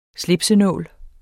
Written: slipsenål

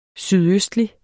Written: sydøstlig

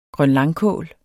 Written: grønlangkål

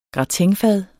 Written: gratinfad

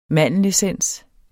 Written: mandelessens

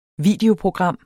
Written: videoprogram

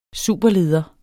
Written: superleder

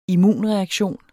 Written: immunreaktion

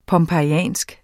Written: pompejansk

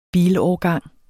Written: bilårgang

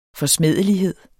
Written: forsmædelighed

